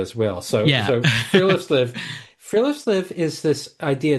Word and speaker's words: friluftsliv: of friluftsliv.